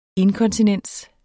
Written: inkontinensen

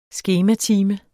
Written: skematime

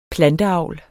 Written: planteavl

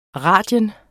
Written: radius

